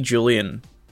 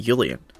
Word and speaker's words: Julian: Like, we see Julian, which, interestingly, I don't know if I've brought this up yet, in the show they pronounce it Julian. And I was like, that's kind of weird. But then I thought, there's a lot of Germanic influence on the show in terms of the Galactic Empire, and the Free Planets Alliance are, like, rebels of the Galactic Empire. So it would make sense if they pronounced it Julian, because in German, the J is pronounced as a Y.